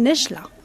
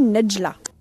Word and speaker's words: Nedjla: Je m'appelle Nejla. N-E-D-J-L-A. Alors on peut le prononcer aussi Nejla.